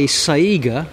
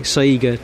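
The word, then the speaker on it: Saïga: I think it's a Saiga, or rather, I think that's how you pronounce it. S-A-I-umlaut-G-A, Saiga Type A13 limousine.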